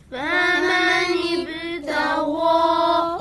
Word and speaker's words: فمن ابتغى: باز هم درست و خوب تلفظ نشد. – فمن ابتغى همین کلمه رو بگید الان. – فمنی بتغا. – آفرین، الان خیلی خوب بود.